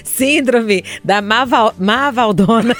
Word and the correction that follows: Malvadona: Bota o L na primeira sílaba. – Malvadona. Malvadona.